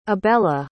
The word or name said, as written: abella